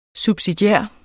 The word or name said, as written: subsidiær